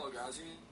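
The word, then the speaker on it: El-Ghazi (אלגאזי): So for the Ma'aril el-Ghazi, el-Ghazi or Ma'aril el-Ghazi? – Oh, how do you say the name? – How do you pronounce his name? – El-Ghazi. Aleph, Lamed, Gimel, Zayin, Yud.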